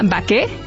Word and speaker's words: Baqué: Se llama Baqué, que viene del anglicismo bake, pero como bien dices, es una panadería francesa con corazón mexicano, entonces es baqué con acento en la E y boulangerie, que significa panadería en francés.